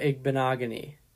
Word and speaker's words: Igbinoghene: And they did draft Noah Igbinoghene. So it'll be Wait, hold on. Let me help here. It's Noah Igbinoghene. – Yes, you are correct. That is how you pronounce his name.